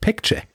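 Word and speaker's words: Baekje: Aber ich habe äh, zumindest äh, Lob bekommen dafür, dass ich Pekche richtig ausgesprochen habe, weil dieses B eigentlich als P ausgesprochen wird.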